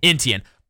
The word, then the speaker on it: Etienne: And before we get to that, by the way, I drafted their first-round pick, Travis Etienne. Is that how you pronounce his last name? – Etienne. – I drafted his.